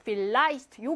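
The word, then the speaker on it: vielleicht: 필라이스트 요거 하나. 필라이스트는요. 필과 라이스트가 합쳐진 합성 부사인데 강세가 여기 뒤에 오거든. 그러면은 강세를 이 자체가 받지도 않고 강세받는 모음 뒤에 있지도 않고 그냥 짧게 feel 하고 발음한다.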